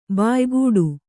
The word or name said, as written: ಬಾಯ್ಗೂಡು